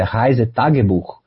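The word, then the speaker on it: Reisetagebuch: Então, assim, o meu livro sobre a Alemanha, Uma Viagem Ilustrada pela Alemanha, a pronúncia correta, né, do livro é Reise Tagebuch, né?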